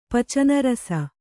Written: ಪಚನರಸ

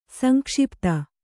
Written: ಸಂಕ್ಷಿಪ್ತ

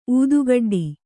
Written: ಊದುಗಡ್ಡಿ

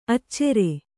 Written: ಅಚ್ಚರಿ

